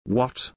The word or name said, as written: WOT